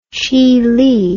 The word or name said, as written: zhili